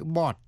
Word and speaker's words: bought: Số 5, sau đây là cách phát âm của những âm tiết O-U-G-H. Có nhiều cách phát âm. Phát âm là O trong chữ bought, B-O-U-G-H-T, bought. Past tense hay là past participle của động từ to buy, nghĩa là mua, G-H cầm, bought. Cough, nghĩa là ho, G-H phát âm là f. Cough drops là kẹo ho. O-U-G-H phát âm là ow trong chữ bow, B-O-U-G-H, bow là cành cây.